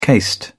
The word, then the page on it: cased: British English